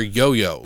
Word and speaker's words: Yu-Yu: It's spelled Yu-Yu in the subtitles, but they call her Yo-Yo, literally.